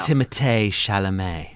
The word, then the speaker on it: Timothée Chalamet: Timothée Chalamet.